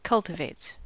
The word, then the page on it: cultivates: American English